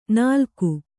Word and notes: ನಾಲ್ಕು: Kannada

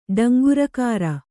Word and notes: ಡಂಗುರಕಾರ: Kannada

